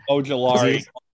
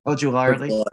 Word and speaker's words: Ojulari: Considering you guys have had an undermanned defense, you're missing your two main pass rushers and Kayvon Thibodeaux and Aziz Anjular and Anjular. Uh, I'm sorry, I butchered that. Ojulari. – Ojulari.